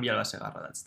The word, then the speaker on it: Villalba Segarra: I'm your host, Mark Kalabian, and today we're here with the CEO of Internext, Fran, and your last name is pronounced Vilaba Segarra, is that correct? – Yeah, just Fran. Fran Villalba Segarra. – Well, it sounds much prettier when you say it. – yeah, I'm from Spain. Usually people from the US, they can't really pronounce it.